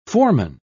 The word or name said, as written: foreman